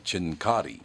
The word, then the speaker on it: Cincotti: World champion Joey Chestnut to defend title against Carmen Cincotti, or Cincotti. Depends on if he pronounces it the American way or the Italian way.